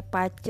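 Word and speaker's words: Bazi: Basi tulisannya Basi dibacanya Pace eh uh,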